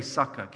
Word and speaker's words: sukkah: Everybody say sukkah.